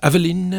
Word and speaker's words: Eveline: Eveline, je moet het op zijn Frans uitspreken, uiteraard.